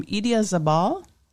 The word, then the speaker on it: Idiazabal: He gave us our 6 months age Spanish cheese from Idiazabal.